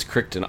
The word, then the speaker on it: Crichton: It's Crichton. I always thought it was Crichton. I've been saying it wrong for pretty much my entire life.